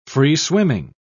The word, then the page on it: free-swimming: British English